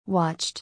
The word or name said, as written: Watched